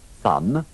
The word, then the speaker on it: son: Questi altri due termini presentano una particolarità nella pronuncia della O e della A. – Son, husband.